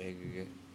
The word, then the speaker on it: Egegik: They found footprints east of Egigik, E-G-E-G-I-K. How do you say that? Egigik? – Yeah, Egigik.